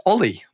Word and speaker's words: O11Y: And I was like, yeah, it's Ollie. That's, you know, you just say Ollie.